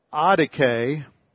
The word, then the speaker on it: adike: Then the other word is adike, A-D-I-K-E, long E. This is from the root, the D-I-K is from the root, same root of dikaios, "dikaio," dikaiosune, the word for righteousness.